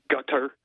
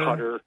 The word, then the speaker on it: Qatar: And I can never remember, because some people pronounce it like gutter. – Cutter? – With a G, but cutter. – It's cutter.